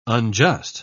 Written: unjust

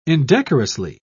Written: indecorously

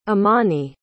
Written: imani

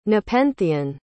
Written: nepenthean

